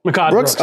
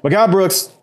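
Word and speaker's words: Mehcad Brooks: And so we got the debut of, and I hope I'm saying it right, is it Makkad? – Makkad Brooks. – Okay. Yeah. Makkad Brooks, Supergirl fame, loved him in that show.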